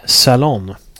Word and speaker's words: Salon: utan det är en intervju i nättidningen Salon, om man uttalar det på engelska. S-A-L-O-N, en nätpublikation.